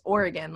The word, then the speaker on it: Oregon: He's like, it's Oregon. Like, don't ever say Oregon. And I was like, crawls under our skin when we hear it. – Right. And everybody I talk to here, they pronounce it Oregon. And I have to correct them. I'm like, it's Oregon.